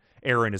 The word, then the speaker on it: Erinne: Aaron is spelled E-R-I-N-N-E, by the way.